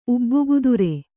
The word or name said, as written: ಉಬ್ಬುಗುದುರೆ